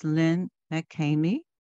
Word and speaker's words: Lynn McCamie: Her name is Lynn McCamey, and we are delighted to have her today.